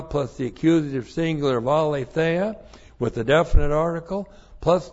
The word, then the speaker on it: aletheia: That's A-L, long E-T-H-E-I-A, aletheia.